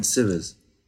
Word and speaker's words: Sievers: I understand you assisted the incredible stills photographer, Wolfgang, is it pronounced Sievers? – Sievers.